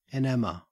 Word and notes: enamour: Australian English

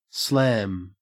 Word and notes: slam: Australian English

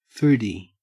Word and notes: foodie: Australian English

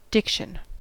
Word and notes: diction: American English

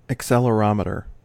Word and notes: accelerometer: American English

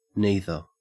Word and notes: neither: Australian English

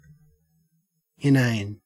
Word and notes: inane: Australian English

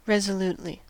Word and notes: resolutely: American English

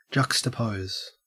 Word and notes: juxtapose: Australian English

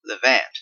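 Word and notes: levant: Canadian

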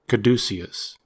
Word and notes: caduceus: American English

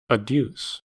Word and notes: adduce: American English

